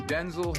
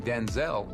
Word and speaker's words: Denzel: My name's actually Denzel. – It is not. – Yes, it is. I'm Denzel Hayes Washington Jr. My father was spelled the same way. His name was pronounced Denzel. My mother started saying Denzel, just made a difference.